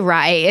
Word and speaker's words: Raey: And this brand, how do you say it? Ray? R-A-E-Y? – I say Ray.